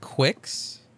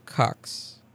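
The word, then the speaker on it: Qux: So it's supposed to be pronounced Quix, according to the trailer. But the founders have embraced the pronunciation Cux referring to the sexual fetish of cuckoldry in which someone watches another individual have sex with their partner.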